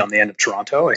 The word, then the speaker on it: Toronto: So most people like here, or most people just say Toronto. Like the second T doesn't exist.